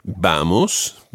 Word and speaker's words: vamos: Vamos, the stress on the first syllable, vamos a, and then the word for to travel is viajar.